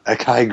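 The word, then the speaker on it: açaí: Acai grape flavored. Fuck yeah. Zero calories. – It's Acai. is how you pronounce it.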